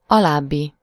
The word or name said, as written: following